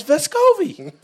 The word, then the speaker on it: Vescovi: And Tennessee's done features on Santi returning home from the summer, where in the video, Santi says Vescovi. He says Vescovi.